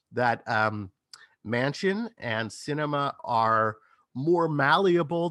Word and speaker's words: Sinema: And meanwhile, we have these at least two Democratic senators, Joe Manchin of West Virginia and Kristen Sinema of, is it Sinema? How do you pronounce it? – Sinema? Sinema, as far as I understand it.